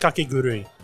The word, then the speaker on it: Kakegurui: Is that how you pronounce it? – It's pronounced Kakegurui.